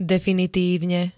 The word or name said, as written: definitívne